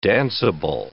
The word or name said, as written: danceable